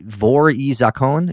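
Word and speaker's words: Vory v Zakone: Vor I Zakon? Is that how you pronounce it, Sean? – Yeah.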